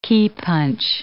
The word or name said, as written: keypunch